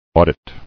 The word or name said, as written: Auditing